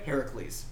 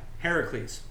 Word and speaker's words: Heracles: So they steal Hercules. Am I saying that right? – Heracles. – Heracles. – Yeah. – Heracles.